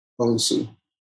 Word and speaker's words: Bonsu: Bonsu. – Bonsu. – Bonsu. – Bonsu. – Like broken bone? – Like bone soup.